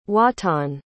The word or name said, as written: wattan